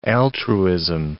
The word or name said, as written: altruism